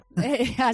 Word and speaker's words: pechá: pero es que aquí decimos pechá. Entonces, además, con esa caída, ¿verdad?, en la pechá. Entonces, sí, pechá.